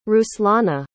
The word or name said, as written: ruslana